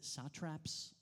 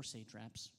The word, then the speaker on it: satraps: And I saw, actually, you can pronounce it satraps or satraps. Either of them is fine. I thought at first I was saying it wrong. But you're fine. Apparently, you could say it a couple of different ways.